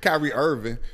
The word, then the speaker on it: Kyrie Irving: Kyrie Irving.